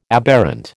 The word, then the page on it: aberrant: American English